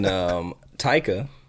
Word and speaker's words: Taika: And then Taika, did I say his name correctly? – Mm-hmm.